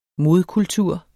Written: modkultur

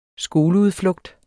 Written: skoleudflugt

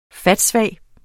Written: fatsvag

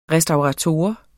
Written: restaurator

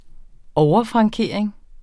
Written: overfrankering

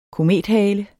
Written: komethale

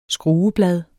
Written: skrueblad

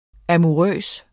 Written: amourøs

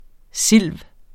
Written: silv.

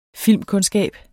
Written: filmkundskab

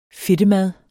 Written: fedtemad

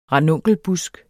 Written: ranunkelbusk

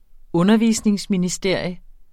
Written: undervisningsministeriet

